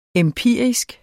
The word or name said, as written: empirisk